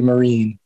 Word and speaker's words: Mirren: So I'll still fall back on Mirren sometimes, but I guess it's supposed to be Mirren.